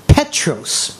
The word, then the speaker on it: Petros: 彼得喺希腊文咧嘅意思就系石块或者系碎石，发音就叫 Petros 啊，呢个系彼得嘅名啊，石块碎石。希腊文嘅判石咧，即系大石眼啊，就叫 Petra。所以耶稣话你系 Petros，但我要建立教会喺 Petra，即系好接近系咪？Petros，Petra。